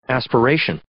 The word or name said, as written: aspiration